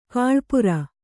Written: ಗಳಪೂರ

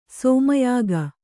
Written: ಶಮ್ಯಾಕ

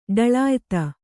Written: ಢಳಾಯತ

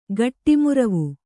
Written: ಗಟ್ಟಿಮುರವು

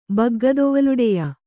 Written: ಬಗ್ಗದೊವಲುಡೆಯ

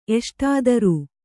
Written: ಎಷ್ಟಾದರೂ

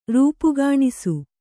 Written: ರೂಪುಗಾಣಿಸು

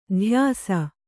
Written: ದೋಯಿಸ

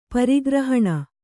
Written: ಪರಿಗ್ರಹಣ

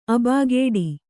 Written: ಆಬಾಗೇಡಿ

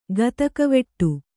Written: ಗತಕವೆಟ್ಟು

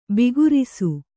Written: ಬಿಗುರಿಸು

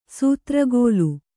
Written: ಸೂತ್ರಗೋಲು

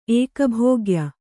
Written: ಏಕಭೋಗ್ಯ

